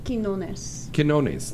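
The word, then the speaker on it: Quiñones: Congratulations to Hugo Gwynos. Gwynos? I think that's correct pronunciation. – Quinones.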